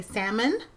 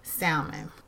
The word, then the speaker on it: salmon: So you're not supposed to pronounce an L in salmon. So you're supposed to say salmon.